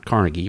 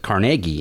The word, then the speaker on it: Carnegie: He went to the Carnegie, that's how you pronounce it, not Carnegie, but Carnegie Institute of Technology, because Andrew Carnegie was from Pittsburgh area too.